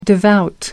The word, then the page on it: devout: American English